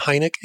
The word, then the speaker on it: Heinecke: My name is spelled Stu, S-T-U, and Heinecke, H-E-I-N-E-C-K-E.